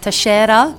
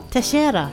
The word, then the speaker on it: Teixeira: If we want to go a bit exotic, it is actually pronounced Teixeira. – Teixeira. – Which I love. – And where's it from? – It's Portuguese.